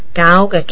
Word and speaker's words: Geauga: I think it must be Geauga County, because that rhymes with Cuyahoga County, which is right next door.